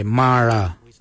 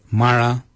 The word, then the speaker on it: Marra: For the number 10, we say Mara. Mara, which is spelled M-A-R-R-A.